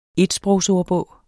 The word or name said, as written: etsprogsordbog